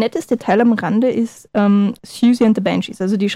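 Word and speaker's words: Siouxsie: Ausgesprochen Siuxi, falsch ausgesprochen Siuxi. – Siuxi, ja, es wird also buchstabengetreu ausgesprochen. Also es ist eine Anspielung natürlich an den Indianerstamm. Und wird ausgesprochen richtig Suzy, oder ne, Suzy nicht ganz so, aber wie, ja, ungefähr wie Suzy.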